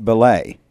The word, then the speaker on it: Belej: And Brian told me his family name is B-E-L-E-J, pronounced Belay.